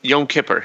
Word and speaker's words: Yom Kippur: Yom Kippur.